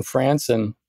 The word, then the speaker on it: Franson: And there was a book written by a guy named Justin Franson. It's spelled like grandson, but it starts with an F, Justin Franson, called Athleticism.